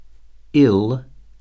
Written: ill

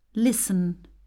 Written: listen

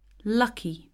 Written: lucky

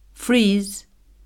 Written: freeze

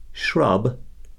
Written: shrub

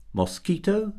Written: mosquito

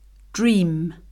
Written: dream

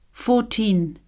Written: fourteen

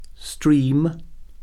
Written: stream

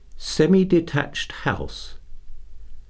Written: semi-detached house

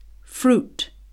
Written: fruit

